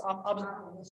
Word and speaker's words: obelisks: The obelisks, obusculus, obelisks. Thank you.